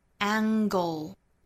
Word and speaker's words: angle: 后面这个角度这个单词呢应该读 angle。